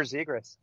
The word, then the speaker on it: Zegras: How do you say it? – Yeah, Trevor Zegras. – Woo, I got it right.